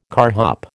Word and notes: carhop: American English

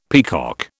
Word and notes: peacock: British English

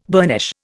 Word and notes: burnish: Indian

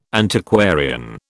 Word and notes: Antiquarian: British English

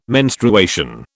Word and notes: menstruation: British English

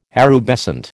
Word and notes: erubescent: American English

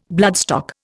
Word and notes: bloodstock: Indian

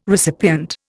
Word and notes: recipient: Indian

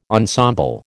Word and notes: ensemble: American English

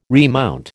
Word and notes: remount: American English

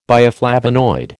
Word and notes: bioflavonoid: American English

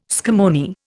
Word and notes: scammony: Indian

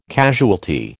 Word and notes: casualty: American English

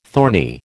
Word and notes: thorny: American English

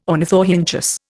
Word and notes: ornithorhynchus: Indian

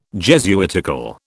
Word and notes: Jesuitical: British English

